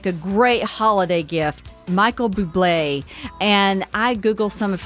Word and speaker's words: Bublé: Bublé.